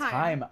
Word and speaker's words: Haim: Okay, it's Haim. – I'm mm. so good at that saying words. – At least like that's how I've heard it pronounced like on their Instagram.